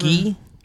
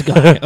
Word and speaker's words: Guy: It's not pronounced Gi? – It might be Gi. – No, it is. – It's Guy. Okay.